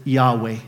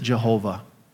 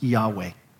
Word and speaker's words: Yahweh: We usually pronounce it Yahweh nowadays. Some people used to pronounce it Jehovah, but we've now come to the conclusion that it was probably pronounced Yahweh.